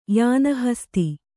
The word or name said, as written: ಯಾನಹಸ್ತಿ